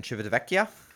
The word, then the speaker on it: Civitavecchia: On the 23rd, you're back in Civitavecchia. – Well done. You practiced. – I remembered how to say it.